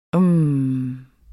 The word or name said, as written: uhm